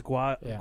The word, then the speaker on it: guayabera: There's no V, Albert. It's guayabera.